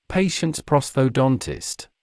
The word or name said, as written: patient’s prosthodontist